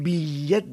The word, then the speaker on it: bitllet: És la seva cosina germana, la ella geminada, que escrivim T-doble-L. És una ella reforçada, com dins les ametlles torrades, o encara la ratlla de França, un bitllet de tren. Escrivim una T, més se pronuncia pas. És just un codi visual per anunciar que cal doblar la pronunciació de la ella.